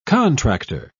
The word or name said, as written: contractor